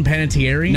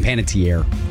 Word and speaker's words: Panettiere: Am I pronouncing her name right? Hayden Panettiere? – No, it's Hayden Panettiere. – Tier. Dang it.